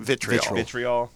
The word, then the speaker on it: vitriol: There's not nearly as much, was it virtual? How do you say that word? – Vitriol. – Yeah. Virtual, what an idiot.